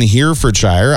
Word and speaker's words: Herefordshire: The popular Scoop ice cream shack in Herefordshire, I actually said that properly. – Good job, I think that's it.